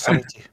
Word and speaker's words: subtlety: It's just subtlety. Just subtlety. It's a common word I use sometimes. – I'm like trying to pronounce the B and the T at the same time, and it's hard. – I know you were trying to. I heard it. It's just subtle. Subtle T.